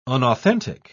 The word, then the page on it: unauthentic: British English